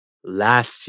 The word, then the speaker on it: last: Last year. La palabra last quiere decir último, pero en este contexto quiere decir pasado. No olvides pronunciar la T al final. Last.